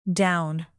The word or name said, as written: down